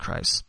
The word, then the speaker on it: Kreis: I believe that's pronounced Kreis.